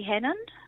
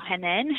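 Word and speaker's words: Hannan: And that was Paddy Hannon. Or Hanan, however you like to pronounce it.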